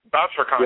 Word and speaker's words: Bouchercon: Is it called, pronounced BoucherCon? – Yeah, BoucherCon, yeah.